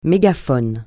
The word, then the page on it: mégaphone: francais